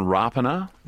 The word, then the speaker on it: Rapana: We've learned in recent weeks that the actual correct pronunciation is Jordan Rapana.